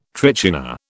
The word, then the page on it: trichina: British English